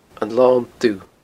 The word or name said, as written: anlondubh